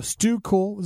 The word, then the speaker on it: S2_Kool: So Stu Kool is the name of the artist. You spell that S2_Kool. So S2_Kool or Stu Kool.